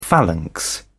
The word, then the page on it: phalanx: British English